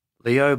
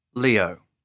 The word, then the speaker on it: Leo: Leo Butler. – Can you spell that, please? – Leo. L-E-O. Butler. B-U-T-L-E-R.